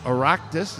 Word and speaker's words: Oireachtas: When I was in Ireland once, we met him over at the Oireachtas. Is that how you say it? – Yes.